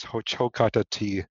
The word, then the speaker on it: Hocokatati: So again, just another resource is Ho-Cho-Ka-Ta-Ti in Shakopee, Minnesota. And that is spelled H-O-C-O-K-A-T-A-T-I.